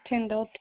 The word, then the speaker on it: Tindall: My first name is B-R-A-N-D-I. The last name is Tyndall, T-I-N-D-A-L-L.